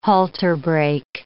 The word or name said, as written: halterbreak